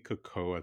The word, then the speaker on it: Cuoco: it has Kaylee Cuoco, I think is how you pronounce the last name.